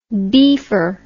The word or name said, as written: beefer